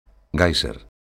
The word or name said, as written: géyser